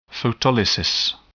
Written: photolysis

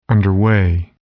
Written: underway

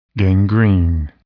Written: gangrene

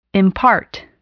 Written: impart

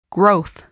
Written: growth

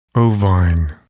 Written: ovine

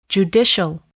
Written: judicial

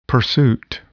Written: pursuit

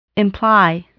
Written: imply

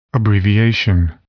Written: abbreviation

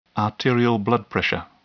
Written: arterial blood pressure

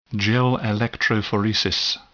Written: gel electrophoresis

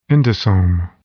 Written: endosome

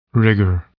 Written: rigor

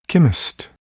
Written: chemist

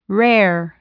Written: rare

